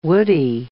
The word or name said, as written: woodie